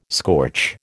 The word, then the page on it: scorch: American English